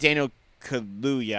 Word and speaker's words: Daniel Kaluuya: I really like Daniel Kaluuya, I think is actually how it's pronounced.